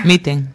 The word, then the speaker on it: Mitten: Tú me has dicho que, siendo latina, se pronuncia Mitten, aunque se escriba con dos E, ¿verdad? – Sí, sí, Mitten, totalmente, okay. Como se lee.